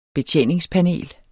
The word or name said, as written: betjeningspanel